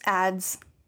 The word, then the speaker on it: Adze: It's just ads. – Ads? – Ads. – Wonderful. – Does that help you? It's pronounced ads.